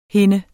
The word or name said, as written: hende